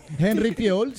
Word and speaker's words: Henry Fiol: Henry Fiol, ¿se dice así? – Henry Fiol, sí.